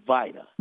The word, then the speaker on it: VITA: This is the time now where people start getting involved in the, now, is it Vita or Vita? – It's Vita. – Vita. I've been saying it wrong all this time.